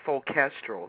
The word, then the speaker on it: Soulchestral: Soulchestral. Yes, it's like soul and orchestral.